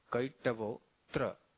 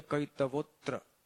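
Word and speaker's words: kaitavo 'tra: For example, dharmaha projjita kaitavo tra. It is atra, but here it is, the apostrophe indicates that you have to skip the A.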